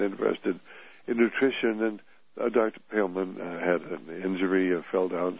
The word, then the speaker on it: Poehlmann: Now, with Dr. Pailman, and his name is pronounced Pailman, those P-O-E-H-L-M-A-N-N, and she has a Ph.D.